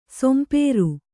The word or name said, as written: ಸೊಂಪೇರು